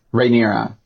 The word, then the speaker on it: Rhaenyra: I feel like you also kind of see that already, like with, is it Rhaenyrin? Is that how you say it? – Rhaenyra. Yeah.